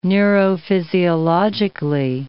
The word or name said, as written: neurophysiologically